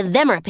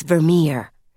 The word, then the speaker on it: Vermeer: But I know you mean the Vemmer painting. – That's Vermeer. Emphasis on the second syllable, Ms. Hussey said quickly, not giving Mrs. Sharp a chance to respond.